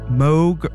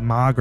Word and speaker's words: Moog: Moog rhymes with Vogue. There we go. Wow. I love you guys in the chat. Thank you. Thank you, everyone. Moog. I always want to say Mog or Moog.